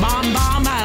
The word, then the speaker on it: Bam Bam: Ed Sheeran and Camila Cabello and Bam Bam. I suppose it should be pronounced bomb bomb. Yeah, to be that Spanish thing.